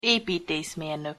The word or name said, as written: epiteszmernok